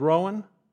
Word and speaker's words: Rouen: And I went through this town, and it's spelled R-O-U-E-N. Have you heard of that sound before? You heard of that town before? You think it's Rouen? You think it's Rouen? Yes! Rouen. I practiced that. Rouen.